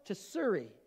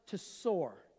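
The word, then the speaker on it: Tsor (צור): Jehovah And it looks like it's going to be Jehovah Tsiri. There it is. All right. That's not how you pronounce it. Apparently in Hebrew, it's pronounced Tsor. All right? So T-S-O-O-R. So the I is silent.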